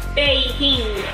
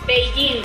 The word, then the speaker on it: Beijing: Beijing. Be Beijing.